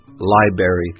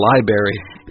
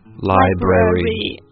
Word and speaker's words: library: Right, and some Americans I've heard mispronounced it before as library, like strawberry or blueberry. Yeah, it's a library. – 尤其在读快的时候非常容易发生这个样子，这样其实就是少了音节。所以 again, 正确的读法应该是 library.